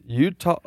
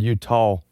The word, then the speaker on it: Eutaw: I guess E-U-T-A-W. – That's yeah. – How would you pronounce it? – Utah. Okay. I was thinking when you said. I thought you said Utah. You did, but Utah. – It's like hee-haw, Utah. Yeah, I would spell it like Utah, the state. – Yeah, well, I think it's pronounced that way. Utah. – It is. – E-U-T-A-W.